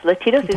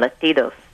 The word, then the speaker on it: Latidos: Latidos is like Latinos, like the Latin people, but with a D in the middle instead of the N, Latidos.